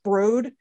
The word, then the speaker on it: Broad: Well, you know, one of Kuhn's big backers is, it's spelled Broad, but I think it's pronounced Broad.